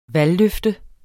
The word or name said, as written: valgløfte